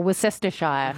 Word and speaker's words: Worcestershire: They do, or Worcestershire, if you're pronouncing it correctly.